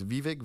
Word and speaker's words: Vivek: Have you watched any of these interviews that Vivek's been doing with all these different. Or how do you say his name? He's Vivek? – Vivek.